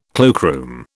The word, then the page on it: cloakroom: British English